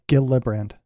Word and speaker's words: Gillibrand: Gillibrand? – It's Gillibrand.